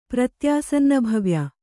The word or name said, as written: ಪ್ರತ್ಯಾಸನ್ನಭವ್ಯ